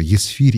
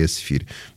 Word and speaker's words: Эсфирь: Кстати, у нас тут потом будет «эсфирь», ну, через книгу, да, «Неемия», потом «эсфирь», ну, тоже «есфирь» или «эсфирь». Я буду читать скорее как «э», но никаких выводов богословских, конечно, из этого делать не надо.